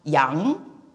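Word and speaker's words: yang (羊): So what you see on your left is the word lamb pronounced yang, and the character me pronounced wo, and the composite character righteousness pronounced yi is righteousness.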